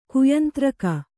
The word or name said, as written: ಕಾಯಂತರೀಕ